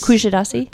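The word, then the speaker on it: Kuşadası: Kushadasi.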